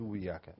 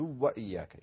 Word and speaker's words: وإياك: فيعني ننبه على بعض المسائل مثل ما قرأ فيها نواف عندما قال نعبد وإياك نعبد وياك، يعني يعني يعني يخفي الضمة أو أنه يخفي الواو فينطقها واحدة يضغمها فيقول إياك نعبد وياك. الصحيح إياك نعبد وإياك، يشبع الدال بالضمة ثم ينطق الواو.